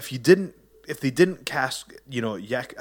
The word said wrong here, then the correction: Joaquin: How do you say it? – Joaquin.